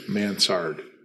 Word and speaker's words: Mansard: Mansard.